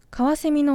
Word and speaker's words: 翡翠: カワセミのオスを翡、メスを翠と表し、オスとメスで翡翠と書いてカワセミと読みます。